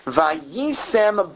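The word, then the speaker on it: vayisem (וַיִּישֶׂם): So the word here, vayisem, that's spelled vav yud yud sin mem, and pronounced that way. Here it's not written and pronounced in two different ways. It's written and pronounced the same way.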